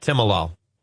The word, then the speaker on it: Timolol: Anyway, the lady was calling about the Timolol. Is it, did I pronounce that correctly? – Right, Timolol.